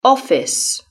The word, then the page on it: office: British English